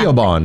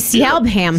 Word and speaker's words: Siobhan: Well, if you look at the name Siobhan, it's spelled with a B. – Yeah, Siobhan. – That would be Siobhan.